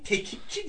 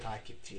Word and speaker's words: takipçi: Bir tekipçi diyor takipçi.